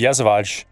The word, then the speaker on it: Yesavage: How do you pronounce that? – I thought it was Yesavage. – There you go.